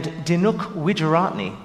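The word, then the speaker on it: Dinuk Wijeratne: So we're going to focus mainly on tabla and Dinuk Widjuratne, the composer. Did I say that right? – Yes.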